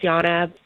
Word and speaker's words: Ionna: So, yeah, my name is Yana. It's Greek and spelled I-O-N-N-A. So it's like a little difficult to know how to pronounce.